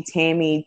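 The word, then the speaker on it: Tammie: So if you see Tammy T.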